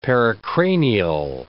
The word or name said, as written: pericranial